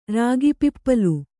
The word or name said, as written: ರಾಗಿಪಿಪ್ಪಲು